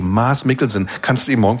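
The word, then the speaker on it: Mads: Und ich darf mal dazu sagen, Mars, so spricht man übrigens aus, ohne D, Mars.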